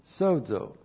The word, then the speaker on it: sozo: Looks like that in the Greek, S long O, Z long O, sozo.